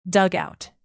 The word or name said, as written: dugout